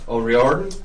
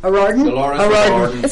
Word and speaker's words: O'Riordan: What's to pronounce her name? – O'Riordan. – O'Riordan.